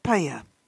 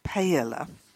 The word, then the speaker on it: paella: It's that Spanish dish, paella, not paella, and it originates in Valencia, the third largest city in Spain.